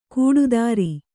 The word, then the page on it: ಕೂಡುದಾರಿ: kūḍu dāri